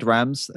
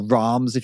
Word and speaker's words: Rams: So it's my YouTube is David Rams. It's like Ram like the animal, but with two M's. So it's David Rams, or Rams if you were German, maybe that's how you would pronounce it.